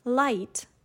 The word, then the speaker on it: light: For example, GH in the word light, you know, the light when you turn on a lamp, was once pronounced with a different sound. For example, it was similar to the German word Licht.